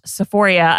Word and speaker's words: Sephoria: Yes, again, that's pronounced Sephoria. I know one time we talked about that and people thought I didn't know how to say the word Sephora, but Sephoria is...